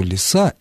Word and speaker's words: лиса: Обратите внимание на то, что лиса имеет ударение на окончании.